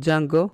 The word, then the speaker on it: Django: Ο Django, The D is Silent,